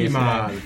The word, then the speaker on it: Limahl: Limahl come si pronuncia? Questa è inglese. – Limahl.